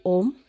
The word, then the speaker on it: Om: So uh, even like when you say Om, so there are like three syllables uh, involved. So A, O. I hope I can say that. Om. So A, O, and then Hum so that's three syllables but when you chant this mantra it's like 100 syllables included so Vajrasattva is a bodhisattva that symbolizes primordial purity.